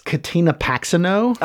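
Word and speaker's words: Katina Paxinou: It's Katina Paxino.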